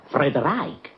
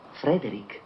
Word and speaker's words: Frederick: Allora dice anche Frederick. – No, Frederick. – Beh, perché non è Frederick Frankenstein? – Non lo è Frederick Frankenstein.